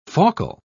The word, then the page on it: faucal: British English